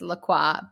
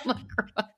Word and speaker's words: LaCroix: I think it is actually supposed to be pronounced LaCroix, but I am an American, so I'll call it LaCroix.